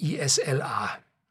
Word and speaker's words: Isla: Und die Brennerei liegt an einem kleinen Flüsschen, schreibt sich ISLA. Ich pron äh, pronounce, also ich betone oder ich spreche das jetzt als Isla aus, weil man ja auch die schottische Insel Islay wird hier geschrieben als Isla betont oder ausspricht.